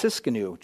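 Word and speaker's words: Tsidkenu: We come to the fourth one now, and it's pronounced Siskenu, Jehovah Siskenu, the T is silent.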